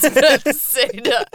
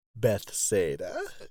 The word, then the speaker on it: Bethesda: And I'm like, "No, I'm pretty sure it's Bethesda." Someone had to show the word to me, and I, oh God. – Oh, I'm sorry to do this to you, but you're pronouncing that incorrectly. It's Bethesda.